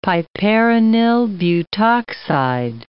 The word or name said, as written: piperonyl butoxide